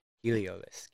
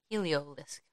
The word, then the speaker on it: Heliolisk: Heliolisk, yep. – Heliolisk.